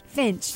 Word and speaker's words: Finch: The thing you can't pronounce is Atticus who? – Fitch. Finch? – Finch.